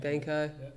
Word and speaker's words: Banco: Banco.